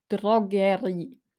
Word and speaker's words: Drogerie: Bei Drogerie hört ihr das R. Drogerie.